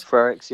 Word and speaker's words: Frerichs: But also, I think they said, is it Frerichs you pronounced, this lady who came second? – Frerichs, yeah.